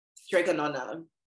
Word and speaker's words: Strega Nona: Strigono- how do you pronounce that? Sorry? – Strega Nona.